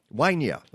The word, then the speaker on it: Winya: Rob Cardillo, Saxon Holt, Matthew Thomas, Mark Turner, Doreen, is it Winja or Winya? – Winya.